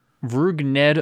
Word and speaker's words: Rougned: Rougned Odor.